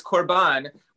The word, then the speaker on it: korban: it's korban, which is almost exactly the same word. You just drop the E sound at the end from the Arabic and you get korban.